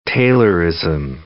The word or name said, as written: Taylorism